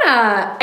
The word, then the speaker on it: Anna: And you sent me her name, and immediately I was like, oh, Anna. And you're like, rhymes with banana.